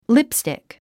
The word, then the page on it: lipstick: lípstìk; リップスティック